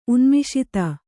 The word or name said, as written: ಉನ್ಮಿಷಿತ